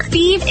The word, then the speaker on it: Fives: Feeve is spelled F-I-V-E-S, like the number.